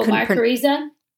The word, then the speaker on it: mycorrhiza: Oh, mycorrhiza.